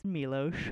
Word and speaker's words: Miloš: Yeah, see, I got it. I saw the accent. – You saw there. – The accent there. – Yeah, it's Miloš. I saw it.